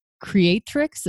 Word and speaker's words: Creatrix: And then how do people find out about its Creatrix? Am I saying that right? – Yes, yes.